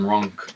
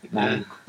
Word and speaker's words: Ronk: Dave Van Ronk. – Ronk, sorry. – Ronk.